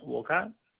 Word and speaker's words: Wolcott: Yeah, it's Ben Wolcott. – Can you spell that? – Ben is B-E-N, and we'll call it W-O-L-C-O-T-T.